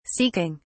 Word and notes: seeking: British English

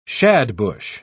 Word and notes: shadbush: British English

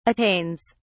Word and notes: attains: British English

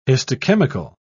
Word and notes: histochemical: British English

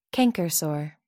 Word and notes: canker sore: American English

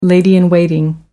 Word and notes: lady-in-waiting: American English

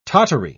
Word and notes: tottery: British English

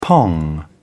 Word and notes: pong: British English

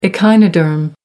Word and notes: echinoderm: British English

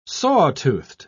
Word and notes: saw-toothed: British English

